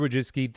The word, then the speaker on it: Wojcicki: Esther Wojcicki, thank you so much for being.